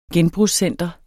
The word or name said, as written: genbrugscenter